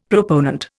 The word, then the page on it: proponent: Indian